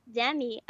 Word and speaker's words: Dami: I'm Dami. I'll spell it D-A-M-I.